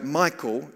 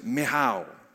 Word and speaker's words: Michal: And we're looking at a lady whose name is spelled M-I-C-H-A-L. It looks like Michal. She's the wife of David, but in the Hebrew, her name is pronounced Michal. Michal.